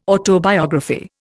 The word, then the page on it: autobiography: Indian